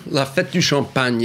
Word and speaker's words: La Fête du Champagne: Well, La Fête du Champagne is really... – Isn't that what I said? – Yeah, well, sort of. You know, I'm trying to discreetly correct your pronunciation.